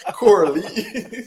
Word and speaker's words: Kohli: You know how he pronounced it when he went to India, right? – Well, he did not pronounce his name properly. – Exactly. – Kohli.